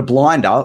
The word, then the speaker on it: blinder: But a blinder, which is sort of, and if you want to use the Aussie accent, you'll say B-L-I-N-D-A, even though you'd spell it B-L-I-N-D-E-R.